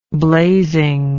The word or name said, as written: blazingly